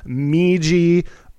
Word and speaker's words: Miji: but it is Miji Lutcher. Miji rhymes with Fiji, and Lutcher rhymes with butcher.